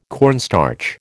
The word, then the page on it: cornstarch: American English